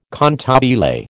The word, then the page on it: cantabile: American English